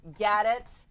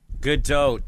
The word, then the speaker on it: Gadot: Pink, Ryan Gosling, Sam Smith, Gal Gadot. – Gadot.